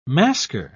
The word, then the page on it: masker: British English